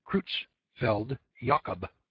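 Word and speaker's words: Creutzfeldt-Jakob: This is the name in people for mad cow disease, and it's pronounced Creutzfeldt-Jacob disease. Although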